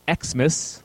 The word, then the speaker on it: Xmas: The first segment is based on Xmas, pronounced as Xmas, like E K S mess, mm-hmm.